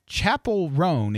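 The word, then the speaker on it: Chappell Roan: Other performers include Camila Cabello, LL Cool J, Katy Perry, Chapel Roan is how it's put phonetically here. I thought it was, it always looks like Chappelle to me.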